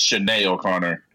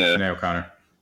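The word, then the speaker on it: Sinéad O'Connor: Rest in peace to Sinead O'Connor, who passed away. How do you pronounce it again? I'm sorry. – I thought it was Sinead O'Connor. – Thank you. Sinead O'Connor.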